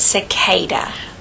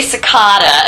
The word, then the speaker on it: cicada: and I'm wondering how you yourself prefer to say it, like cicada or cicada? – That's actually a very common question. I get that one a lot. And actually, I pronounce it cicada.